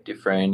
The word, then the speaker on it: Dufresne: But then we jump in and we jump to a courtroom where they're asking Mr. Dufresne, Did I say that right? – Dufresne, yeah.